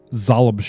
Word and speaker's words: Xoblob: And it says Old Zalab Shop. And that's spelled X-O-B-L-O-B.